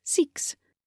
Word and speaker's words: Seaques: You can find Mirror Secret Mirror by Jessica Seeks, pronounced Seeks, but spelled SEAQUES, on Amazon, Apple, and all the major ebook retailers.